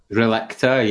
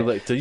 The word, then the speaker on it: Relicta: Relicta, yeah. – Relicta.